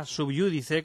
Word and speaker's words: sub iudice: Los latinismos peccata con dos Cs, peccata minuta, sub iudice con acento en la U, vox populi, motu proprio, que así se dice con la R entre la P y la I, aunque es complicada la pronunciación.